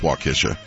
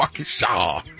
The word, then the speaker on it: Waukesha: Yeah, it's Waukesha. – It's Waukesha? – Waukesha. – Waukesha? Kisha? – Kish. – So we were both wrong? – Kisha. – Kishaw. – Kishaw. – Got to say it like that? – Oh, yeah. Waukesha.